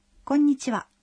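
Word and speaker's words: конничива (こんにちは): То же самое можно сказать «ава» в слове «конничива» – «добрый день». Произносится «ва», но пишется «ха».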